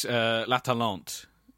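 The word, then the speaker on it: L'Atalante: It's La Talente, listeners. That's how it's pronounced. Not how I said it.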